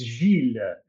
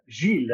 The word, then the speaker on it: Gilles: Uh, please, Gerard, uh, it is pronounced Gilles. Gilles. Eh? Gilles. Uh, Gilles. Like, uh, like an eel that you can eat from the sea uh, with a G at the start.